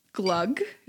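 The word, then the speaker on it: glögg: I've had glug.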